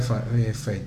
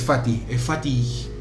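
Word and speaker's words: Fatih: Yo para mí era Fate, mirá. Es Fati con H. Fati Akin.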